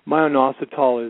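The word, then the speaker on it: myo-inositol: Myoinositol is a,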